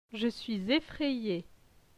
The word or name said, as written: Je suis effraye